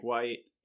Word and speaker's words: Wight: So I mean, if you want to find me on the internet, like I am on LinkedIn at slash Mike Wight, and uh, I have a website wight.ink again spelled like my last name w-i-g-h-t dot i-n-k where I blog a little bit.